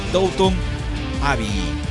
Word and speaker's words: Downton Abbey: Mejor actriz de reparto de miniserie o película para televisión, Joan Frontman por la serie Downton Abbey. Así lo pronuncia, así me han enseñado que se pronuncia, así que lo vi por la radio también.